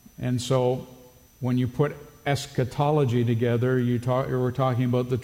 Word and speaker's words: eschatology: The term is actually from the Greek, and in English it's pronounced eschatology. It's made up of two primary words, eschatos and ology.